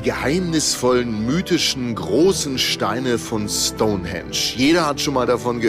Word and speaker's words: Stonehenge: Stonehenge. Ja, man spricht es wirklich mit Betonung auf Henge, wohlgemerkt.